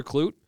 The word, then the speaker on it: Klute: Clute. Like rhymes with flute. – Oh, that's easy now. – All right. – Okay. Tyler Klute.